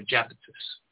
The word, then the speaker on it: Iapetus: The I in Latin is pronounced like a J. So it's Iapetus or Japetus.